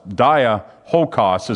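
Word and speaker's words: Diolkos: That's how it's, Diahokus is how it's pronounced, which was a stone roadway where ships portaged across that isthmus.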